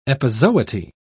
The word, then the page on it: epizooty: British English